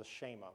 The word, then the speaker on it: Shema: Am I pronouncing that right? Yes, the Shema, which can be found in Deuteronomy.